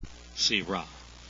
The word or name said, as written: syrah